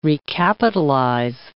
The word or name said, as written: recapitalize